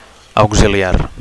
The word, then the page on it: auxiliar: Catalan